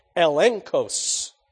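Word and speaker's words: elenchos: Conviction here is the Greek word eklos. Eglekos. I'll say it right yet. Elenkos.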